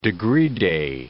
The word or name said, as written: degree-day